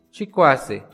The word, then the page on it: Chikwase: Náhuatl